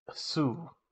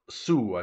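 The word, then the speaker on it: Hsu: And Stephanie Sue. – Sue, I think is how you say that.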